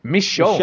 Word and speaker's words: Michonne: Michonne.